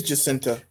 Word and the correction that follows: Jacinda: It's Jacinda.